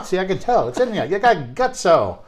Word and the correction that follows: gusto: Gusto. I said that wrong already.